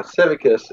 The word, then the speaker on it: Civicus: The other game that's on your website is Civicus. I hope I said that right. It's been years since I've taken Latin, so. – Yeah, Civicus, and basically that's the more complicated strategic game.